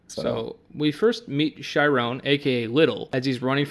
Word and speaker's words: Chiron: When we first meet Sharon, is it Sharon for pronunciation? – It's either film, it's Chiron.